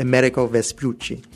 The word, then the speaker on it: Amerigo Vespucci: Amerigo Vespucci.